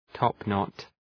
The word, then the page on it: topknot: ‘tɒpnɒt